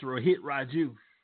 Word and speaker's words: Rohit Raju: It's Rohit Raju. – Rohit Raju. It sounds like Ryu from Street Fighter.